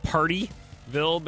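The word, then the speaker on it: Pardeeville: It sounds like Partyville, but it's spelled P-A-R-D-E-E, Partyville.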